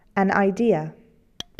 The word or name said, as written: idea